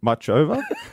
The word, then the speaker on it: Muchova: Ash Barty is on court this morning against 25th seed Caroline Muchova.